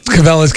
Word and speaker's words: Cabela's: We went through the Cabela. Is that how it's pronounced? – Cabela's catalog.